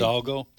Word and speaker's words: Hidalgo: In fact, in this AP story, am I pronouncing this county right? Hidalgo County? – Hidalgo.